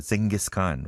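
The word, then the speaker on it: Dschinghis Khan: DSCHINGHIS, Khan. 뭐, I guess it is, pronounced Genghis Khan, right? But it is spelled in a German way.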